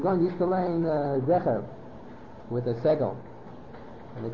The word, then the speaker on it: Zecher: That the word is Zecher, it's not Zohar.